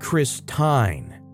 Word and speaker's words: Christine: She says, "The I is long because of the E at the end of her name. It should be pronounced Chris Tine."